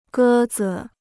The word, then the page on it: 鸽子: gē zi